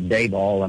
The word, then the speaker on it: Daboll: And I'm impressed with your pronunciation of Daboll. I mean, it only took me about five different tries to get the name right.